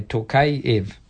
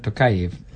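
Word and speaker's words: Tokayev: And if you want to pronounce Kazakhstan, the E-V in the end is always separate to the other one. So you say Takayev, President Takayev.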